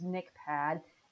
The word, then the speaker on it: NCHPAD: There is a really awesome resource out there that, for whatever reason, just a lot of people don't know about, and I'm probably going to say it wrong, but it's NCHPAD. NCHPAD, National Council on Health, Physical Activity and Disability. It's close. – But that sounded spot on to me.